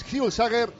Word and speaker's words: Hjulsager: En el izquierdo, el danés Hjulsager y como punta, Gidetti. – Y fíjate, es la primera persona que pronuncia bien Hjulsager.